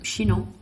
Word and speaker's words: Chinon: So we're now trying Domaine Bernard Baudry Chinon, 2014. – Bernard Baudry Chinon. – Yeah. – What? – What? – You said it right.